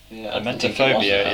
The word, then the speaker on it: emetophobia: Emetophobia? – Emetophobia is a phobia that causes overwhelming, intense anxiety pertaining to vomiting.